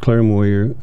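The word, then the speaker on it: Clair Moyer: Yes, my name is Claire Moyer.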